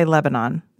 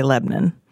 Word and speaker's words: Lebanon: It's called Lebanon. You may not say Lebanon. You may only say Lebanon. Or they will make you leave.